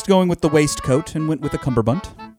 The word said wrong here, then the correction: cummerbund: I feel like it's a cummerbund. – A cummerbund? – I feel like it's not like a bunt cake.